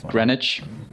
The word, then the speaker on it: Greenwich: It's Greenwich.